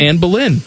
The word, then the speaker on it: Anne Boleyn: Anne Boleyn.